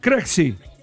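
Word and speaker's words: Krejčí: El apellido checo, Krejci, se pronuncia de esta forma. A ver. Fonética aproximada en español, Krej. Y vea en checo, Verrián. Detalle por sílaba. Krej suena como Krej, con una E abierta y una J que se pronuncia como la Ixe en rey.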